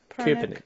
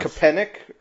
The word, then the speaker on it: Köpenick: K-O with a umlaut over it, P-E-N-I-C-K. – Köpenick. Yeah, sure. – Okay. – Close enough. – Köpenick, or it's east of Berlin.